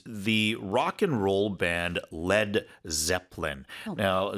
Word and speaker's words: lead: LEAD인데 리드라고 읽지 않고요. 납일 때는 LED 이렇게 발음하거든요.